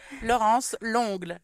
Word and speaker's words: Laurence Longle: Laurence Longle.